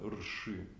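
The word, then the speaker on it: рши (ऋषि): А, например, риши, на самом деле это рши. Риши, да, знаете термин, это мудрец.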